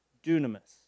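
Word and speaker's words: dunamis (δύναμις): This is how it's pronounced, dunamis.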